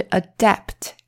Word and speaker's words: adapt: 好，所以这个就是 adapt 跟 adopt 的差别哦。 A D A P T 还有 A D O P T 中间的母音不同。发音也不同，意思也不同，可是真的长得非常像哦。